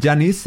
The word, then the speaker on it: Janitsch: Bueno, Johann Gottlieb Janis es un compositor. ¿Cómo se escribe? J-A-N-I-T-S-C-H.